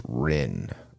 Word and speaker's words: Rijn: I believe his his name is pronounced Rin. It's it's spelled R I J N, but I, I believe his name is Brad Rin.